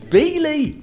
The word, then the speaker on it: Beoley: B-E-O-L-E-Y. – Bioli. – No, it's not Bioli, it's pronounced Bealey.